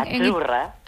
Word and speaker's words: Azzurra: Azurra. – Azurra. Mira, ¿ves? – Azurra. Es que... Sí, como porque pizza, la Z no es la Z como es decimos en catalán tan sonora, no.